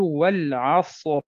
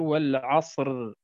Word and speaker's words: والعصر: مثال كلمة العصر، كيف نتلفظ العصر عند الوقف؟ الراء تكون ساكنة والصاد ساكنة التي تسبق الراء، فننظر إلى العين التي تسبق الصاد عفوا فهي مفتوحة، فكأن الراء مفتوحة فتفخم، فنقول والعصر ولا نقول والعصر، واضح؟